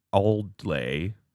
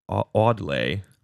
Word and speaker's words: Awdlay: Christmas carols in the English language first appear in a 1426 work of John Audley. – Audley. A-W-D-L-A-Y. – That's tricky. – Audley.